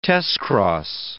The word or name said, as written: testcross